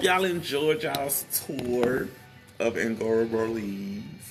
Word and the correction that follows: Agora Borealis: Agora borealis. – Agora borealis. It's almost like aurora borealis, but instead of aurora, it's agora.